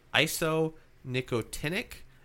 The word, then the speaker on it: isonicotinic: P-A-S. Okay. – PAC. – Yes. The next one was isonicotinic.